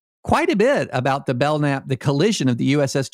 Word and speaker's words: Belknap: It is spelled Belknap, which is how I kept pronouncing it. But we have been reliably informed that it is, in fact, Belknap. The K is silent.